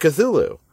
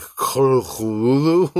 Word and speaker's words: Cthulhu: It's funny you should sort of gag on pronouncing that, because it's not pronounced Cthulhu. – Oh. – That is how it's spelled, and so that's how everyone's used to saying it. But Lovecraft kind of went out of his way... The author who created Cthulhu went out of his way to say that it should not be pronounced that way. He gave a couple of like weirdo pronunciations, but like I think one was closer to like Cthulhu, like kind of drunky.